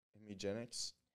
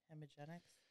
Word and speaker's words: Hemgenix: Hemi Jenks. H E M G E N I X. Hemigenics. – Hemigenics.